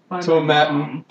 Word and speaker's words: Tomatin: Established in 1897, Tomatin, to rhyme with satin.